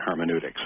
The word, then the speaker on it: hermeneutics: And other people's view is you got to take it literally, and that would be just two different hermeneutics. Is that, did I say that right? – Yeah, that would be two different hermeneutic approaches, yes.